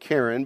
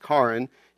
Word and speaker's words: Karin: He said it's kind of like Karen, but it's pronounced Karin.